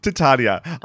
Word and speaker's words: Titania: Titania.